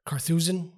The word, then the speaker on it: Carthusian: Carthusian? – That's correct as well.